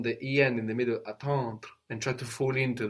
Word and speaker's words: attendre: Attendre. – Attendre. So emphasizing much more the EN, no, and the accent does fall there as well, will help you fall into the next sound. You're concentrating on the next sound, no? The D-R-E, which is making you pronounce it too much.